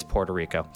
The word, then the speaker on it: Puerto Rico: No, I didn't say it right, buddy. It's Puerto Rico. So, you know, even though I'm from Miami, you know, Miami Beach, born and raised, I'm going to say Puerto Rico.